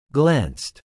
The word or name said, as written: glanced